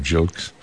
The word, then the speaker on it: Gilkes: His last name spelled G-I-L-K-E-S, and emphasized the pronunciation of Jilkes on trombone.